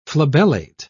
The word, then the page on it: flabellate: British English